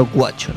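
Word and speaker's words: Güacho: Guacho se escribe con diéresis en la U. – Sí. – Porque se pronuncia Guacho, ¿no? – Sí.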